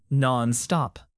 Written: nonstop

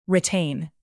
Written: retain

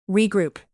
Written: regroup

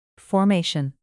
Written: formation

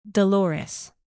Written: dolores

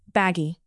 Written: baggy

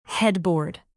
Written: headboard

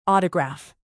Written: autograph

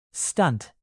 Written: stunt

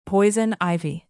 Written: poison ivy

